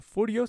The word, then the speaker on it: Furyo: Furio Sensei, se dice Furio, se escribe F-U-R-Y-O Sensei con S.